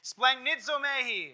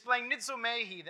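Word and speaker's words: splagnitzomai (σπλαγχνίζομαι): The compassion that Jesus had, this splagnitzomai, I did it wrong again, splagnitzomai, I always miss that first syllable. This splagnitzomai that he felt always compelled him to action.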